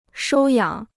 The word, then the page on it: 收养: shōu yǎng